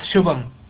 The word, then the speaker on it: Shubham: It's Shubham.